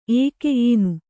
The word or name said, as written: ಏಕೆ